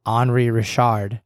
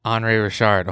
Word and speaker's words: Henri Richard: Henri Richards. – Henri Richard. – Henri Richard.